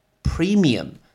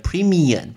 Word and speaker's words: premium: 保险（insurance），insurance，投保（insure），insure，保单（policy），policy，保险费（premium）。 Premium，你在念的时候，那个嘴巴一定要关起来哦，因为如果你没有关起来，你如果拼会拼成 n 的发音，就变成 premium，不一样哦，是 premium，确定嘴巴一定要关起来。